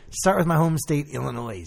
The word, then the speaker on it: Illinois: It's Illinois, fucking. Don't ever say Illinois. – Illinois. – You sound like an idiot. It's Illinois. – I know it's Illinois. – I know the S is silent.